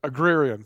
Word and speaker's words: agrarian: Agrarian, am I saying that right? Agrarian, thank you. I put too many syllables in there.